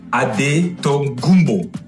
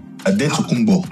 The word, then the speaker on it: Adetokunbo: So in the D, they put NT, mm-hmm. and in the B, they put MP. – Ah, okay. – So it's pronounced Adetokunbo in the Greek way.